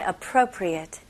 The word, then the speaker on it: appropriate: 네, 강세는 pro에 넣어주시면 되고요. 그래서 appro, 그 다음에 appropriate. 만약에 여기에 8라고 발음하시면 appropriate라고 하면 뜻이 달라집니다. 그거는 주의하셔야 돼요. 적절한이라는 의미로는 appropriate.